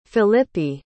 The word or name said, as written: filippi